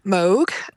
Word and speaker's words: Moog: Yeah, well, she was working with Bob Moog, as I kept being told is the correct pronunciation. We always say Moog here, incorrectly.